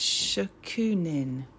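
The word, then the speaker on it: shokunin (職人): So on your website, you talk about the Japanese word shokunin.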